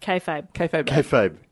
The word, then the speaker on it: kayfabe: No, you said kayfabe. – Okay, good. – You said it right for the first time because you said it wrong every single time last week.